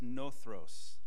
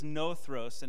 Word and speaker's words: nothros: The word is nothros. I don't know Greek, but uh, I, I listen to how they say it, so just mimicking that. Um, but the word is nothros, and it means lazy or sluggish.